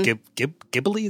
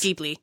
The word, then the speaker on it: Ghibli: like from Studio Ghibli. Is that how you say it? – Yes. Yeah. – Yes. – Ghibli.